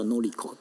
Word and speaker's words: 法子: 酒井法子，然后法子的正确日文应该叫 Noriko 对不对？